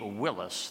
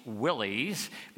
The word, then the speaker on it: Willys: Once I signed the title, I named my Jeep Willis, the original pronunciation of the name of the founder of the Willis Overland Company, which was awarded the contract to produce the first World War II Jeeps. These days, people call Jeeps Willys because of how the name is spelled, W-I-L-L-Y-S, but I prefer the original pronunciation, Willis.